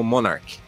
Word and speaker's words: Monark: É assim, pra, pra facilitar, pra não distrair tanto durante meu vídeo, eu tô pronunciando ele como Monark, que é o, a pronúncia em inglês.